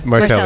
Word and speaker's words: Marsaglia: Marcella. Marcelia. Marcelia. Silent G. Okay, Silent G.